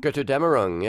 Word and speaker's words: Götterdämmerung: You know, I knew he was kicking, was at, how do you pronounce it, Götterdämmerung? – Götterdämmerung, yes.